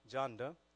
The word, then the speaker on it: Jonda: Dr. Ron Cruz is the Executive Director of Grace Churches International, which we are associated with, and his lovely wife, Jonda. It's pronounced like Rhonda, but Jonda, okay?